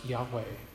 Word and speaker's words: Yahweh: It's referred to as the Tetragrammaton, YHWH, usually pronounced Yahweh, today. But we don't actually know how it was pronounced by God when he spoke it, because Hebrew doesn't have vowels. And so we don't know exactly how it was pronounced.